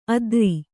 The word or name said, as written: ಅದ್ರಿ